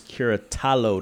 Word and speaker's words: Curattalo: I am with Mr. Nicholas Curattalo.